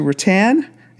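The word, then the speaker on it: Roatan: And then it's going to go to Rattan. In Honduras, again, I'm sucking on these pronunciations. It's R-O-A-T-A-N, Honduras.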